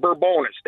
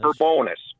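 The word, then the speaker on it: Bourbonnais: They knew the Bourbonus family pre-1900. They pronounced it Bourbonus. They were trappers on the Kankakee River, and they were also farmers. And they said Bourbonus.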